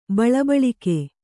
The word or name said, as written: ಬಳಬಳಿಕ್ಕೆ